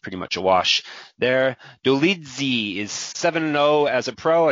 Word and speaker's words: Dolidze: Roman Dolidze.